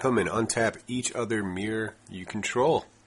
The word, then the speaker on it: Myr: And he's a Mire. I hope I'm saying that right. – I think it's Mirror. – Oh, yeah, that would make sense. Mirror. – Mirror, yeah. – I think it's Mirror, M-Y-R.